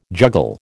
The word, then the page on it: juggle: American English